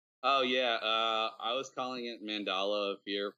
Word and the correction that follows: Mandala: for a long time, and then Amy corrected me because that's not right. It's Mandala of Fear, Mandala.